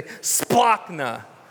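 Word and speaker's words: splanchna: Everybody say splachna. Yeah, it's kind of a fun one. You got to, if you don't get a little spit coming out, you didn't say it right.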